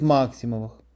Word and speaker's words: Максимовых: Как рассказывал дядя Никита, живший ребенком в Белебее в 1920-30-х годах, старожилы говорили ему, что он не из Максимовых, а из Максимовых, подчеркивая важность ударения на первом слоге, и рассказывали о знатности основоположника фамилии.